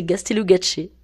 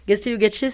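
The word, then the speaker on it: Gaztelugatxe: Alors c'est Gastélugatché. Gastélugatché,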